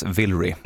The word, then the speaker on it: Vilray: Det här är alltså en man i övre medelåldern som kallas för, eller som heter Villery. Jag tror att det uttalas Villery. V-I-L-R-A-Y.